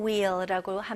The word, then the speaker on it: wheel: 영어로는 wheel or steering wheel라고 합니다. Rotate the wheel. 휠 아니에요. H는 묵음입니다. Rotate the wheel.